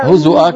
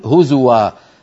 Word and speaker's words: هزوا: هزؤا كما نطقت على قراءة نافع. نعم أذكر المشاهدين أختنا نور أيضا من تونس قالت أتتخذنا هزؤا، هكذا يقرأ نافع هزؤا، ونحن برواية حفص نقول هزوا، هذه صحيحة وهذه صحيحة، هذا كلام عربي وهذا كلام عربي.